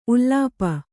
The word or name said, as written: ಉಲುಪಿ